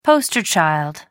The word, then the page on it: poster child: American English